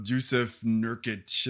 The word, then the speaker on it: Jusuf Nurkić: And, you see Dario Saric and all these other people that you never heard of, Jusuf Nurkić. And I know that I'm not pronouncing any of those guys right. But you actually pronounced both of those correctly.